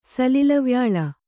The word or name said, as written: ಸಲಿಲವ್ಯಾಳ